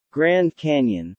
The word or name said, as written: Grand Canyon